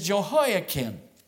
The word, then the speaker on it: Jehoiakim: All right, the first is Jehoiakim. Jehoiakim, if you are taking notes, let me spell it for you. It's J-E-H-O-I-A-K-I-M, Jehoiakim.